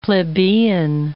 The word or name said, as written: plebeian